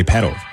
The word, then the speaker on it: Petal: saw an interview recently where Ariana Grande was pronouncing the album, also the song off the album, Petal, and she like emphasized the T, and I was like, ugh, Petal. I think we just all would say Petal, right?